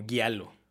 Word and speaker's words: giallo: Siempre lo pronuncio mal. ¿Giallo? Pues yo, he escuchado que lo pronuncian como Giallo.